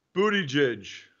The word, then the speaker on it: Buttigieg: Mayor Pete, how do you say his last name? – Buttigieg. Buttigieg. – Wow. You had it right out of the gate.